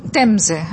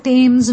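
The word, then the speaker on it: Thames: রিভার আইসিস কিন্তু নয়, boat পিকনিকটা হয়েছিল রিভার টেমসে। হ্যাঁ, বাঙালি উচ্চারণে ওটা টেমস হয়ে যায়, টেমস নয় টেমস,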